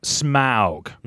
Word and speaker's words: Smaug: Pronounced Smaug.